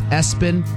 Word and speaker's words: ESPN: Like, one person had ABCDE pronounced Absidy, or ESPN pronounced Espen, Ursula, Bertha, and then on the list is Todd.